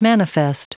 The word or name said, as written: manifest